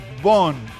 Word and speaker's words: Von: Me dicen, no es F, no es con F, es Von. En vez de, lo que pasa es que se menciona Von, pero es Von, es V-O-N, ¿ok? Me dicen cómo se escribe Von, V-V-N, espacio, D-P-E-L, con dos P, D-I-P-P-E-L, repito, V-O-N, espacio, D-I-P-P-E-L.